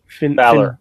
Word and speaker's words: Finn Balor: Finn Balor.